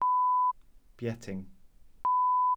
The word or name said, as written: bieting